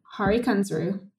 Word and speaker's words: Hari Kunzru: Hari Kunzru. Is that how you pronounce it? – Hari Kunzru.